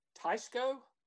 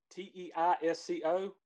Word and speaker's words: Teisco: His first guitar was a Teisco, T E I S C O, Teisco Del Rey WG41 that he bought from Sears.